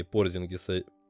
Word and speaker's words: Порзингис: И на самом деле, вот без шуток, я бы его не глядя бы махнул бы на Парзингиса, если была бы возможность. – Мне кажется, многие болельщики Далласа тоже с тобой согласятся все-таки особенно в текущем состоянии Порзингиса, как это правильно говорить именно Порзингис, Марканен выглядит как минимум интересным.